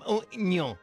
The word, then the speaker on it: Mourinho: Pero es que no lo mencionaste, no lo pronunciaste bien. José M. Mourinho. Así.